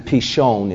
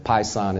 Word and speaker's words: Pishon: So the first river is it's actually Pishon is how it's pronounced, but Pison is how most people pronounce it.